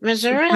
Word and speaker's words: Missouri: And then I moved back to Missouri. Is that how you say it? Missouri? – Missouri. Yes.